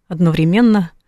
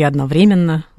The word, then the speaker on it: одновременно: Ох, со временем все и просто, и сложно одновременно или одновременно. – Угу. – Говорят, что это слово у «одновременно» или «одновременно» можно ставить ударение в разных местах.